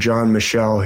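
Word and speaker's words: Michell: There's a great researcher named John Michel. He's now passed away. It's spelled like Mitchell, but without a T. And I believe it's pronounced John Michel.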